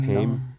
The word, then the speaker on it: Haim: But you're a Haim fan as well. – I'm a Haim fan, yep. I always would say Haim for a long time. I've been corrected since.